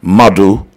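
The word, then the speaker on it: Madhu: And then you're going to go on YouTube and type in Madu. That's my artist name. And then music, but spell it with a Q, M-A-D-H-U, then space, M-U-S-I-Q, Mother Music.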